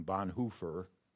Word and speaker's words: Bonhoeffer: So then I want to come to uh, the grace of God in sanctification, and for that, I turn to Dietrich Bonhoeffer, or as they pronounce it in German, Bonhoeffer.